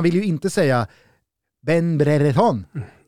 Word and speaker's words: Brereton: men man vill säga Ben Brereton med liksom engelskt uttal.